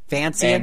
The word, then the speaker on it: Vancian: The Venetian casting system, uh, Vanakin, Vancian. Vancian. I never pronounced it right the first time.